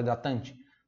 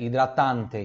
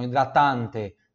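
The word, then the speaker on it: idratante: Só que a gente fala hidratante, mas é hidratante. Então vai ter que ter a pronúncia pura do T aqui, tá? E o A mais aberto. Então hidratante, tá?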